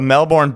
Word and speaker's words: Melbourne: Melbourne. That's how people should pronounce it, Melbourne, but yes. We just say Melbourne.